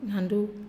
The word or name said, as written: nandu